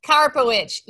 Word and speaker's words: Karpowich: We got Krista Karpowich. Is that how I say your name? – Almost. Karpowich. – Karpowich.